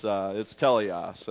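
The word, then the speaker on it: teleos (τέλειος): We were mispronouncing it, and he's like, guys, it's teleos.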